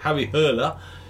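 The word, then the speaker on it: Harry Hole: Very recently, me and my other half have been watching on Netflix a show called Detective Hole, or as it's pronounced, we think, Detective Hurler, because it's Norwegian, and it's based on some Joe Nesbo books, and it's about a guy called Harry Hurler, I think that's how you pronounce it.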